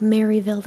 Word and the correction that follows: Merrillville: That's not how you say it. It's, like, Merrillville.